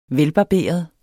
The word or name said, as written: velbarberet